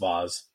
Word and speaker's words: Vase: It's spelled V-A-S-E, but it's pronounced Vaz.